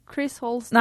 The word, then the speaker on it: Chris Holsten: Chris Holsten.